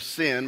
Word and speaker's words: Sin: They've come to the wilderness of Sin, which looking at it, it looks like the wilderness of sin, but it's actually pronounced "Sin," which means thorn.